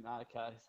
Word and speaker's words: Arnica: I'm not even saying it right. It's the cream. Arnica. – It's Arnica. Yeah. Arnica cream. – It is Arnica cream, yeah.